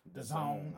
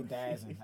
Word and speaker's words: DAZN: The Zone. I always say DAZN.